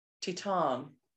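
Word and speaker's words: Titane: We come on to the final film, the closing gala, which was Titan. That's how we're pronouncing it, right? French? – I think so, because it's French, yeah.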